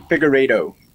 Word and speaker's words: Figueiredo: Davidson Figueiredo.